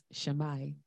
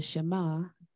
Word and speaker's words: Shema: Last week, I mispronounced the Shema as Shammai. The Shema is Deuteronomy 6, 4 to 5.